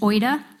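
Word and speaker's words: oida (οἶδα): Okay, now there's another Greek word for the word know, and it's pronounced oida, and it's spelled O-I-D-A.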